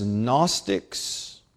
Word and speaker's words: Gnostics: Theologians, people who study the Scriptures, normally refer to them as Gnostics. The word spelled with a silent G at the beginning because it's from the Greek word for knowledge.